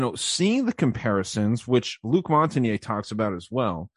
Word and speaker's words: Luc Montagnier: One being Luc Montagnier, I think is how you pronounce his name. If you want to look him up, L-U-C is his first name. Last name, M-O-N-T-A-G-N-I-E-R. Luc Montagnier is how I would pronounce it.